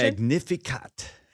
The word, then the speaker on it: Magnificat: That's where we get this Mary's song of praise, otherwise called the Magnificat. Is that the correct pronunciation? – The Magnificat.